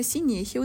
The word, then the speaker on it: синехия: Мы немножечко неправильно выразились про хирургическое вмешательство, а это называется официально синехия, именно синехия, ударение на е, это когда врожденное или приобретенное как раз-таки сращение малых половых губ.